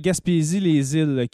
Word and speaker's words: Gaspésie-les-Îles: Et puis 20h30, c'était Gaspésie-les-Îles. Que... – Exactement, merci. Je cherchais la prononciation exacte. Et voilà. Alors, Gaspésie-les-Îles, en ce moment, on va faire un premier résultat, voir si tout le le, système fonctionne.